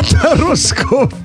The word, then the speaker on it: Тароскоп: А вы слышали, как правильно произносится рубрика? Это вам не Таро за ебуриха, а Тароскоп.